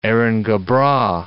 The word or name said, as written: Erin go bragh